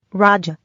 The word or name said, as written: Raja